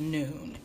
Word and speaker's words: Nun: It's spelled N-U-N, but it's pronounced noon, N-O-O-N.